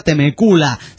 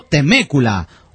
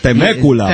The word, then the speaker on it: Temecula: La ciudad de donde escribe el Poli Chafa no se pronuncia Temecula, sino Temécula. – Temécula.